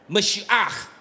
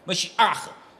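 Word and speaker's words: Mashiach (משיח): 弥赛亚 Mashiach。大家如果读不出来没有关系，这就是希伯来文的发音 Mashiach。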